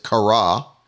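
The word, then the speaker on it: kara (χαρά): We see it's the word kara, and you can see there's the phonetic spelling so that you know how to pronounce it, kara.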